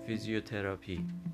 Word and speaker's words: فیزیوتراپی: پس شاید بهتر باشه که بگیم فیزیوتراپی. فیزیوتراپی، کما اینکه اکثر افراد من میدونم به همین شکل تلفظ میکنن، اما خب مخاطب این گفتار اون دسته از افرادی هستن که این رو به شکل فیزیوتراپی بر وزن ترابی، آقای ترابی، خانم ترابی ادا میکنن.